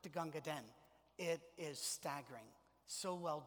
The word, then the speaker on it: Gunga Din: It's actually pronounced Gunga Din, but it's D-I-N, and so people call it Gunga Din.